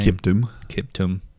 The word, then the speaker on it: Kiptum: Kelvin Kiptum, is that how you say his name? – Kiptum. Yeah.